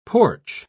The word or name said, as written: porch